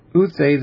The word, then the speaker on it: Uthai: I think I'm going to say, when it's not accented, I'm going to pronounce it as A. Uthai,